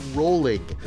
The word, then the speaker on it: Rowling: Rowling. – Rhymes with bowling, it says.